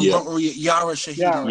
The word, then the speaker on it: Yara Shahidi: And he, I hope I don't butcher his name, Yahari Shahidi. Am I saying that correct? – You mean that, the person on Yara Shahidi.